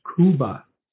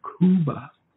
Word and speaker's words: Khooba: He writes, another unique symbol in Aramaic is Kuba. It's spelled in English, K-H-O-O-B-A. In Aramaic, it's a symbol. Kuba.